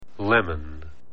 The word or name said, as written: lemon